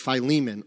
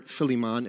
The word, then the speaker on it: Philemon: and Philemon, or Philemon if you want to say it wrong.